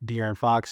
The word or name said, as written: De'Aaron Fox